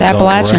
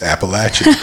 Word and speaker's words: Appalachian: I know to say Appalachian to not get booed off stage. So. – That's right. Yeah, you got to say it right. It's Appalachian.